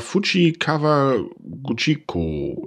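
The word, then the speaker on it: Fujikawaguchiko: Und zwar Fuji Kawaguchiko. Ich hoffe, ich habe es falsch ausgesprochen, wie immer. – Nein, du hast schon richtig ausgesprochen.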